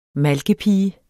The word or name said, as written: malkepige